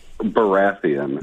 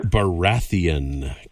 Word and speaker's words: Baratheon: How do you pronounce his last name? – Baratheon. – Baratheon. King Robert Baratheon.